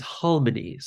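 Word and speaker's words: halmoni (할머니): And what I associate harmony to is the Korean word for grandmother, which is halmoni. So you would spell it more like H-A-L-M-O-N-I. But I just have this memory, and I think I wrote about this many years ago in an unpublished essay.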